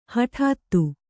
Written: ಹಟಾತ್ತು

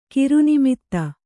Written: ಕಿರುನಿಮಿತ್ತ

